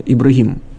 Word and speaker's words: Ибрагим: Только в исламе он чуть-чуть по-другому произносится, на арабский манер, не Авраам, а Ибрагим.